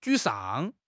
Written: 沮丧

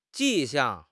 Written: 迹象

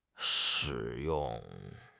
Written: shi3 yong4

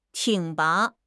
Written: 挺拔

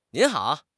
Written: nin2 hao3

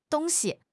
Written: dong1 xi5

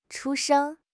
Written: chu1 sheng1

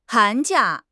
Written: han2 jia4

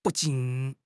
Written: bu4 jin3

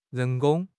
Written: 人工

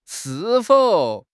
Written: shi4 fou3

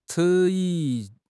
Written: 迟疑

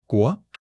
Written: 国

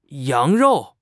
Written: yang2 rou4

